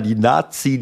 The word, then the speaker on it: Nati: Nazi. In Deutschland recht schwierig, wenn man es so feiert, aber so wird es halt ausgesprochen. – Genau so, also die Nationalelf, die Nati geschrieben, ich habe irgendwas haben die da gesungen.